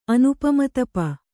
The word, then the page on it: ಅನುಪಮತಪ: Kannada